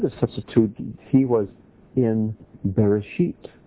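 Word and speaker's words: Bereshit (בראשית): Now in Hebrew, the word or the phrase in the beginning is one word, Bereshit or Bereshis as some pronounce it.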